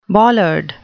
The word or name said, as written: bollard